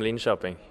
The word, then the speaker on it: Linköping: I come from Linköping, Östergötland.